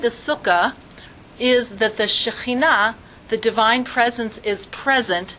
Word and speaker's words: Shechinah: Shekhinah, S-H-E-C-H-I-N-A-H, Shekhinah. – Shekhinah? – That would be, if one didn't know how to say the ch, then they would pronounce it like that, but it's a Shekhinah.